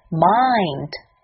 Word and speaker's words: mind: 第二个单词 Mind， M 的发音是 M，双唇闭拢，气流从鼻腔中泄出，m。英语中发这个音的时候，一定会出现字母 m。接下去字母 i 的发音是 i，首先发 a，再慢慢滑向 i，音要饱满，切记不要发成中文 i 的音。